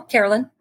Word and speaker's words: Carolyne: No, Carolyne.